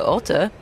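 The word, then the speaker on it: Otte: Roger Federer, 4-3-4 against Oscar Otter. I was taught that pronunciation by Boris Becker.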